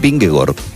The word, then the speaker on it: Vingegaard: La doble A en los idiomas escandinavos se pronuncia como una O. Es decir, el ciclista danés Vingegaard es Vingegaard, por raro que suene.